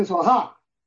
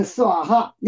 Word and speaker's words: 斯瓦哈: 是这样的，念得快了就说哈，实际上是斯瓦哈，念快了说哈，是这样的。